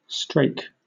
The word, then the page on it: strake: British English